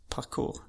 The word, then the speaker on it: parkour: Uttalades det parkour? Eller är det kanske för att du upptäckte i England just? – Ja, det kanske det är. – Jag säger, det låter parkour. Som ett franskt ord.